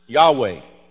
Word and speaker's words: Yahweh: It has no vowels. YHWH. Now, we Gentiles had to add vowels so that we could pronounce it, Yahweh.